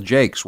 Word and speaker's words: Jakes: No, I lived in the Senate district that was the state Senate district that was represented by his predecessor, a woman named Cheryl Jakes, rhymes with fakes, one of the ultimate phonies that ever, even by state house standards, she was a phony's phony.